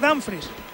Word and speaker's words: Dumfries: Lo digo bien, Dumfries. – Tengo dudas en esa posición. ¿Cómo es exactamente? – Yo creo que es Dumfries.